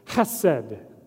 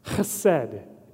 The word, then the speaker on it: chesed: And it's this Hebrew word, it goes like this, chesed, chesed, H-E-S-E-D. You really got to get that throat into it in order to say it like the true Hebrew.